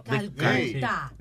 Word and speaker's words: Calcuta: Madre Teresa de Carputa. – De Calcuta. Pero Dios mío, Calcuta. Calcuta. Pronuncien bien la vaina.